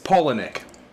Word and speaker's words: Palahniuk: We've been pronouncing it Palahniuk as long as we've known each other. It's Palahniuk.